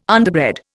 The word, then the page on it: underbred: Indian